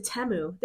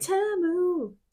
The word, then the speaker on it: Temu: I think they pronounce it Temu. They're like, Temu, Temu. – Oh, it's Teemu.